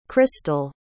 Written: Krystle

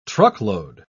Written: truckload

